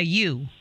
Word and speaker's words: ewe: It's E-W-E. – That's crazy. – Ewe, yeah. – A ewe.